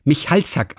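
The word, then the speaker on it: Michalczak: Also, mir wurde von den Vorfahren übertragen, dass man diesen Michalzack ausspricht. Also ohne C. Michalzack geschrieben mit CZ aber ausgesprochen nur mit Z.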